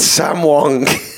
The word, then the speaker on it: Sam Wong: Alive and well, it's Sam Wong. – What word are you rhyming with there? – Wrong. – Oh, wrong, right. – Of course, wrong.